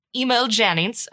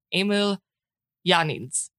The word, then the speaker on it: Emil Jannings: Professor Emanuel Roth, played by Emil Jannings, I totally screwed up the pronunciation of that, Emil Jannings.